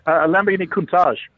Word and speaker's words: Lamborghini Countach: Lamborghini Countach.